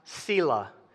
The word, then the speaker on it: Selah: This word at the end of verse 6 is pronounced Selah. I know because I looked it up. I've heard it pronounced Selah, I've heard it pronounced Selah, and I've heard it Salah. And all of you probably have heard it different ways, but the one that has the most backing is the pronunciation Selah.